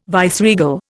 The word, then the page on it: viceregal: Indian